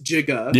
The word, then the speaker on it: giga: And giga did say pronounced jigga.